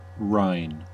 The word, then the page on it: Rhine: American English; IPA: /raɪn/